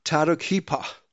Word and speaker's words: Tadukhipa: If I can pronounce her name correctly, Tadukhipa. Tadukhipa. Tadukhipa.